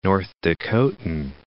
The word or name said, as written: North Dakotan